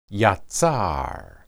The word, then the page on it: yatsar: hebrew